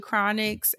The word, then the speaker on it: Chronixx: And then I also have a record with Chronics and the Free Nationals, who I absolutely love.